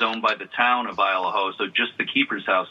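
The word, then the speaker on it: Isle au Haut: It's spelled ISLE space AU space HAUT, but they pronounce it Isle of Ho, up in mid-coast Maine.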